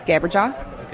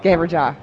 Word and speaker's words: Gabberjaw: It is Gabberjaw. Okay. That's with a hard G. – The hard G. – Mm-hmm. You know, like G. Gabberjaw.